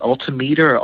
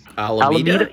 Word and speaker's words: Alameda: I think am I saying the name right? Is it Altameter or Albeda, California? – Alameda? – Yeah, there's a place where they do amazing screen printing, and I've done some projects.